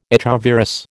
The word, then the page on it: echovirus: American English